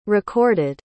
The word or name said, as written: recorded